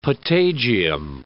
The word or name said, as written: patagium